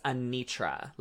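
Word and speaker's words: Anetra: I reviewed the looks already on my Twitch stream, and apparently it's Anitra. Like, Anitra is how you say it.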